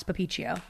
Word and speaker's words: Papiccio: So you can find me on Instagram, Twitter, everywhere, just at Alex Papiccio, my name. – How do I spell that? – A-L-E-X-P-A-P-I-C-C-I-O.